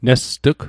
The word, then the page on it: nest'g: Mi'gmaq